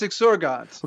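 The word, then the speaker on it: exsurgat: Exorget or exorgat? – Okay, I was confused as a hard G or a soft G. – Is it followed by an A or an E? – A. – Oh, then it's exorgat.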